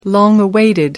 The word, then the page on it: long awaited: American English